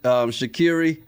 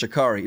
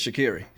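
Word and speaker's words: Shaqiri: By the way, Dorica does ask, is it Shakiri? I thought it was pronounced Shakari. No, it's spelt, but she even has it. If you look at her Twitter handle or X handle, it's Carrie. To give the pronunciation, if you ever hear her say it, she'll tell you it's Shakiri.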